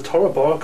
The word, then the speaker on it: Tora Bora: Or was it the Tora Bora complex, am I saying that right? – Yes.